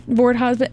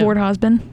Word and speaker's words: Vordhosbn: It sounded like you said it right. – Vord Hosbin. It's spelled V-O-R-D-H-O-S-B-N. – Oh. – How would you say that? – How you said it. – Vord Hosbin.